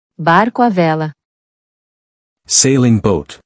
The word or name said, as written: Barco a vela